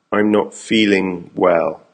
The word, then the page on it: I'm not feeling well: American English